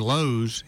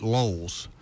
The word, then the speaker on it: Lowe's: Another buddy of mine from Chicago, he instead of saying Lowe's, he pronounced it Lowell's.